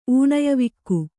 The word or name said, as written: ಊಣಯವಿಕ್ಕು